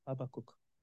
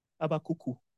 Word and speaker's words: Habakkuk: That's the right pronunciation, right? Habakkuk. Habakkuku. In Yoruba, Ekwa Church, we used to say Habakkuku.